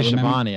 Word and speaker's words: Schiavone: I always mispronounce it. – It's actually Schiavone, I think. – I think it's Schiavone, yeah.